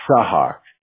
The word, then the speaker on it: Sahak: And then when you call in, just ask for Sahak, or Hawk is even fine, H-A-W-K. That'll be the easiest way. And my full name is a little harder to pronounce. It's Sahak.